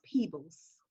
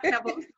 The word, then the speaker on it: Peebles: My last name is pronounced Peebles. – Peebles, I'm sorry. – Not Pebbles.